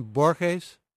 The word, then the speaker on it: Borges: Do you mean Borges? – Borges, I do.